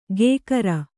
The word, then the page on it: ಕೇಕರ: kēkara